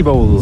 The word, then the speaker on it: bowl: Il se prononce comme bowl. It's a bowl. – Bowl, mais c'est pas comme un bol. – C'est B-O-W-L.